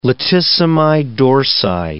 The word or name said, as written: latissimi dorsi